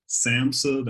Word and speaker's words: SAMHSA: It's called SAMHSA. The H is silent, but S-A-M-H-S-A. And they specialize in substance use if you need help with substances.